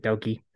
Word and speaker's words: Doki: Yes, it looks like it says Dookie, but you pronounce it Doki.